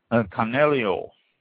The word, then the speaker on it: Canaiolo: Next one, Canelio, or Canelio, pronounced both ways, spelled C-A-N-A-I-O-L-O.